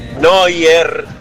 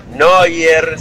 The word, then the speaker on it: Neuer: Neuer. Neuer se pronuncia. – Bueno, Neuer.